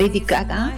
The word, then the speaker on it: Lady Gaga: Este es Dance, Lady Gaga.